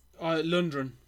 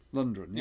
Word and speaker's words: Lundgren: How do you pronounce it? Do you pronounce it Lundgren or Lundgren? – Lundgren. – Lundgren, yeah.